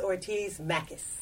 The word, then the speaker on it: Ortiz Mackes: I am sitting here with a returning guest who has made some big changes in her life, Liz Ortiz Mackes.